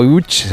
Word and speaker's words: Łódź: Aunque se pronuncia Łódź, se escribe Lodz.